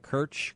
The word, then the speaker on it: Kerch: Am I saying it right, Kerch? Kerch Straits? – Yeah.